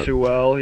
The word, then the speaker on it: Sewell: Washington got Sewell. I probably said his name wrong. – Penei Sewell, yeah.